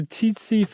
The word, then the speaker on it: tsetse: No, because I think the tsetse fly, is that how you say it? – Yeah.